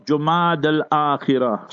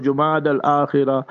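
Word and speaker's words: Jumada al-Akhira: So you can pronounce it Jumad al-Ukhra or Jumad al-Akhira. And Jumad al-Akhira is better according to some ulama. But both are permissible. So whether you say Jumad al-Ukhra or Jumad al-Akhira, that's the proper pronunciation.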